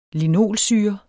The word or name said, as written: LINOLSYRE